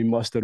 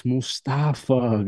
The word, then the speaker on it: Mostafa: I just tell everybody, please don't call me Mustard or Mufasa. You know what I'm saying? Growing up in the States, different communities, man, they butchered my name left and right. It's like, oh, Mufasa. I'm like, no, it's Mustafa. Guys, I'm not talking about the Lion King, please.